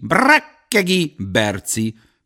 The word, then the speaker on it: Brekegi Berci: Két K és két Y, leírva Y, kiejtve I. Brekegi Berci, mondta nyomatékkal, és farzsebéből elővett egy névjegykártyát.